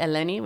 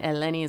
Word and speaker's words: Eleni: My Instagram is at Kasiani Eleni, which is my name in Greek.